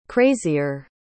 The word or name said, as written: crazier